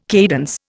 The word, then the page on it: cadence: Indian